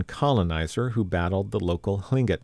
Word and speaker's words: Tlingit: Yedikuka Dion Brady Howard introduced the dance program earlier in the event and gave the crowd a lesson in Tlingit, especially the transition away from the former pronunciation of Tlingit with a hard K sound that doesn't exist in the language.